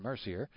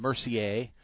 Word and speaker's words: Mercier: Years ago, I asked Jason Mercier, and a lot of people call him Mercier, which is the French pronunciation of the ER at the end of a word. And he said, no, it's Mercier.